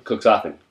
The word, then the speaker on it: Cuxhaven: Cuxhaven. Cuxhaven. And that pronunciation, because I have a German girlfriend, is correct. Okay. She drilled it into me.